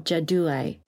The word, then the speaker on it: Jaduai: Tell me if I'm pronouncing this correctly. The Jaduai. – Correct. Excellent job.